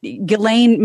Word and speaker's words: Ghislaine: but Ghislaine Maxwell, at least that's the way I've heard it pronounced through multiple podcasts about Epstein, she has been arrested.